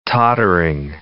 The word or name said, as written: tottering